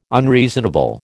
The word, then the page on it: unreasonable: American English